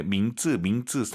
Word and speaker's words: 名字: 이름 발음하실 때 민츠 민츠 쌍지 아니에요? 민츠 민츠 이성경성 아하 기억하시면 좋을 것 같습니다.